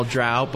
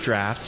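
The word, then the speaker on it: draught: Well, drought, but it's actually pronounced draft, so I'm going to look that up.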